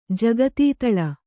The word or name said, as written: ಜಗತೀತಲ